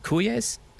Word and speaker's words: cuyes: They're called uh, I'm going to say it wrong in Cuyes C U Y E S, cuyes in Spanish is what they're called.